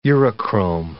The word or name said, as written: urochrome